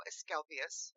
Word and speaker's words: Asclepius: So they see the Aselpus. Is that how you pronounce it? – No. – The thing that's not Codicus. Aselpus? – No, Asclepius. – Asclepius, right? And what bothers me is Asclepius. – Asclepius, thank you.